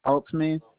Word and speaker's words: Altman: Altman. – Can you spell that? – Altman.